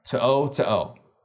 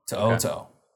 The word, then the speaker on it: To'o: I've looked up the pronunciation. I've listened to the pronunciation. No. To'o. – Henry To'o. – To'o. Got it.